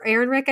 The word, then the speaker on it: Ehrenreich: Uh, this movie is also starring Florence Pugh, Robert Downey Jr., Rami Malek, Alden Ehrenreich, or Ehrenreich, I think is how you say it. It looks like Reich, but it's Rick.